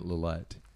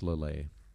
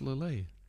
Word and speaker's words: Lillet: I think until recently I was pronouncing it Lillet. And then I realized that it's pronounced Lillet. – I still call it Lillet. – Yeah. But it's Lillet.